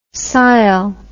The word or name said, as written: sile